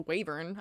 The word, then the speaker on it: wyvern: I mean, there's also wavern. I call it wavern sometimes, but I think it's pronounced wyvern.